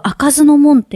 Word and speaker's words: 不明門: 城の、実は開かずの門っていう、不明門って書いて、開かずの門って読むところがあって、はい、普段は入れないところを開けていただいて、そこでライブをしたんですよ。